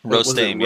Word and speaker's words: Rostame: It was Rostame, right? – Rostame, yeah. He pronounced Rostame.